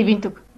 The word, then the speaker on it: Windhoek: Eh, y, cómo, ¿Y cómo es Vos vivís en una ciudad que se llama Vintuk, Vintuk, algo así. – Sí, Vintuk.